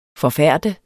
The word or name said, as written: forfærde